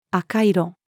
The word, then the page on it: 赤色: female